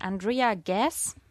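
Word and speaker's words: Andrea Ghez: Reinhard Genzel heißt der Mann und er hat zusammen mit seinen Kollegen und Kolleginnen Roger Penrose und Andrea Gass habe ich es richtig ausgesprochen? – Jawohl.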